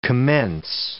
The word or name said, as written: commence